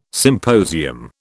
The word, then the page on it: symposium: British English